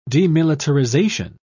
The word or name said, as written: demilitarization